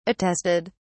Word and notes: attested: British English

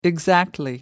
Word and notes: exactly: American English

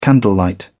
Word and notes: candlelight: British English

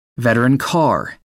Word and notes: veteran car: American English

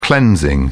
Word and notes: cleansing: British English

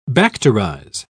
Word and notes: bacterize: British English

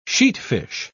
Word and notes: sheatfish: British English

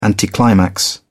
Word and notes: anticlimax: British English

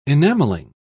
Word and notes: enamelling: British English

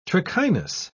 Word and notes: trichinous: British English

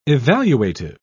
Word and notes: evaluative: British English

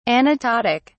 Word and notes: anaptotic: British English

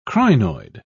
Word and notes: crinoid: British English

